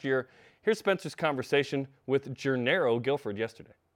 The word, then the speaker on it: Gennaro: I am Jarom Jordan, and we begin today's show with the conversation that happened yesterday after practice with Gennaro Guilford, which, by the way, I've been saying Gennaro forever. We found out it's Gennaro, so join me in pronouncing that correctly.